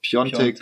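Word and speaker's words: Piątek: Und äh, was die für Spieler teilweise ausgegeben haben für den, ich weiß nicht, wie man ihn ausspricht, ich glaube, also Piatek schreibt man ihn, aber Piontek, Piontek spricht ja.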